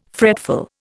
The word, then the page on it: fretful: Indian